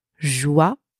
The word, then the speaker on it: joyeux: The final X is silent. So far, we have joyeux, joyeux.